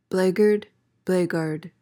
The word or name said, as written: blackguard